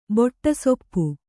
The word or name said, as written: ಬೊಟ್ಟಸೊಪ್ಪು